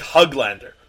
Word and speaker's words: Höglander: I just learned it, so I had to like watch some YouTube videos on how to pronounce that O with the two dots on it. And after watching a few of those videos, I learned that it's kind of like, it's like Huglander.